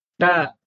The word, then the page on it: t: Sanskrit